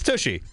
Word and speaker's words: Tushy: Am I saying that correctly? Tushy? – I don't know how else you would say it. – Touche? – No, it's not that fancy. – Okay, it's Tushy. – Yeah.